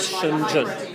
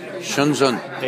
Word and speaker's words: Shenzhen: Yeah, but the sh, you put the tongue in the back of your throat, it's a retroflex. Shenzhen. – Okay, But people who don't know that, they say Shenzhen. – Right.